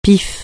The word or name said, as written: pif